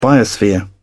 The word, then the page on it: biosphere: British English